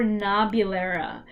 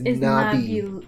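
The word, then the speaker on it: Nabulera (나빌레라): okay, so it's either, I see both, it's Naviolera or Nabulera. I'm pretty sure... – yeah, I think it's Naviolera, but the pronunciation is Nabulera. – Yeah, Nabulera. Yeah.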